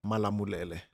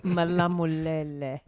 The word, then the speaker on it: Malamulele: Say that again. – Malamulele. – I'm definitely going to try that one. – Malamulele. – You actually said it right, yeah.